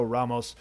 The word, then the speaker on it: Ramos: My next pick is going to be in the matchup between Charles Jourdain and Ricardo Ramos. It's probably Ricardo Ramos, right? Because if the R is the first letter, you pronounce it as an H when it comes to Brazilian names and the such.